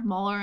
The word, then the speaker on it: Mueller: Mueller investigation. – Mueller investigation.